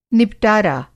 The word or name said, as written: निबटारा